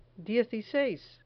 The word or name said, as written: dieciseis